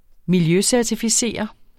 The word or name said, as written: miljøcertificere